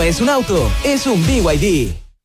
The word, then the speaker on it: BYD: Los VIP. – ¿VIP? ¿No es VIP? – Nada que ver, se escribe BYD, pero se dice BYD, la marca número uno de electromovilidad en Chile y el mundo.